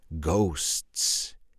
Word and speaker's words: ghosts: Now remember the pronunciation of the word ghosts in the plural. In the singular, it's ghost, and in the plural, it's ghosts.